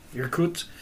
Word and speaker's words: Irkutsk: Irkutsk.